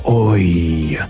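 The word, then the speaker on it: oïl: Tam słówko współczesne oui wymawiano jako oi.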